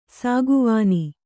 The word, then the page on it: ಸಾಗುವಾನಿ: sāguvāni